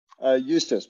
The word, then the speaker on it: Eustace: Eustace, but close.